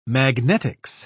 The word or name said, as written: magnetics